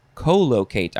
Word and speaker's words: collocate: But see, I would actually want to pronounce it collocate. I want to emphasize the first syllable, but the only approved pronunciations are emphasizing the second and third syllable. So I will say collocate, because the word locate is in there.